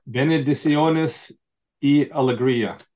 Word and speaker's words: Bendiciones y alegría: Bendiciones y alegría.